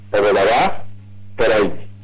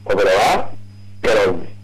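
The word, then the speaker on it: Oberabá Caray: Oberabá, Caray. Así se pronuncia. Oberabá, Caray.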